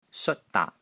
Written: SEOT1 DAAT6

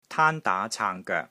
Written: TAAN1 DAA2 CAANG3 GOEK3

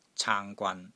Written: CAANG1 GWAN3

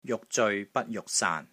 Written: JUK6 ZEOI6 BAT1 JUK6 SAAN3

